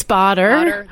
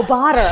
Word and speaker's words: Bader: Well, yeah, we've tried to figure, I think batter, Bader, we don't know. – I think it's Bader. – There it is. – Oh, Bader.